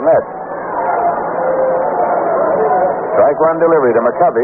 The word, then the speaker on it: McCovey: You know, during his entire minor league career, this fellow's name was pronounced, and he pronounced it McCovey. And he pronounced it McCovey when he came to the major leagues, but nobody else did, and he finally just gave up and said, all right, call it McCovey. So everybody does.